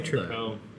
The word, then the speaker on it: Tripel: I say Tripel. Even when I see it on the shelf when other breweries have made it, I'm like, Tripel. It deserves deserves more emphasis on the last syllable, I think.